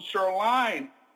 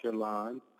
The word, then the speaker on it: Cheurlin: We want to, you know, the French pronunciation is called Sherline. Here in the United States, some people will say Sherlin because of the I, and it's Cheurlin.